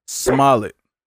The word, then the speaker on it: Smollett: I think it's Jesse Smollett. – Smollett. – That's how you pronounce it, yeah.